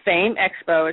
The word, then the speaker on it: PHAME: And, again, I just want to let everybody know that the FAME Expo, it's spelled P-H-A-M-E Expo.